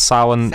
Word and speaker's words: Samhain: So the festival is Samhain. – Sounds scary. – It's Irish mythology, like everything's a bit scary in the fringes. But Samhain itself, it's a liminal time of year.